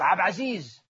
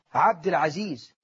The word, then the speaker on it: عبد العزيز: هو اسمه خالق، اسمه خالق، وبعده لك عبد عزيز ويلزق اسمه عبد مش عبد، عبد العزيز، عبد الوهاب، مش ربنا مش اسمه وهاب، كل وهاب مش وهاب، هذا كله أيضا الحاد في أسماء الله بنطقها بغير المنطوق الصحيح.